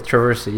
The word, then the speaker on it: Traversy: Yeah, Traversy, yeah.